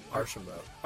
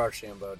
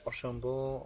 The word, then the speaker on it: Archambault: Archambault. – Archambault, okay. – Yeah, or Archambault, depending on what part of Canada you're from. – Archambault. – Yes, Archambault or Archambault.